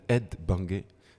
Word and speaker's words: Ed Banger: Qui était tenu par un mec qui s'appelle Ed Banger. Qui a formé son label, en fait. Ça se dit pareil, mais ça s'écrit pas pareil.